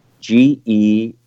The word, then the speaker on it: geranyl: I'll spell it G-E-R-A-N-Y-L. Gyronol.